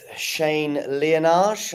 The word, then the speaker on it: Shane Liyanage: I'm with Shane Liyanage.